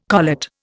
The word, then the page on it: collet: Indian